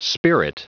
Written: spirit

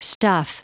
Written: stuff

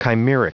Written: chimeric

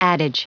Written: adage

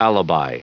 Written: alibi

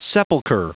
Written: sepulcher

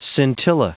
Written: scintilla

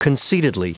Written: concededly

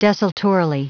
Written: desultorily

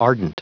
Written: ardent

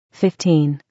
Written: 15

